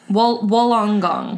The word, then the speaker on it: Wollongong: at the New South Wales Fire Brigade's Communication Center in Wollongong. I hope I'm pronouncing that right. – Say that again. – Wollongong. Wollongong.